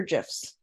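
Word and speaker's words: GIF: Do you call them GIFs or GIFs? – It's obviously GIFs. Hard G. – Okay, I agree. L-E-Z underscore dish.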